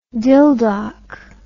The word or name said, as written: dildok